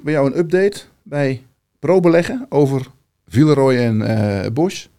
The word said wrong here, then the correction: Boch: Ja, officieel is het Villeroy en Boch.